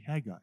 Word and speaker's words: Haggai: I don't know whether anybody can relate to me or not, but growing up, I remember in early years always being taught that the pronunciation of this book was Haggai, and so that carried with me for a long time. I only learned later that the correct pronunciation is indeed simply Haggai.